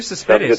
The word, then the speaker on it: Cespedes: I believe Cespedes. – Good to me. – Cespedes is how you should say it. – Cespedes. Yoenis Cespedes. – Cespedes. A little more emphasis on the middle syllable there.